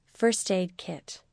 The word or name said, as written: first aid kit